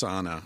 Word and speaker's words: Ana: See, there's no Anna in Spanish. It's Anna, especially with one N.